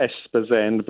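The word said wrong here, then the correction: Esposende: but the Portuguese tend to pronounce it Spazend. They drop all the vowels at the beginnings and ends of words here. – Spazend.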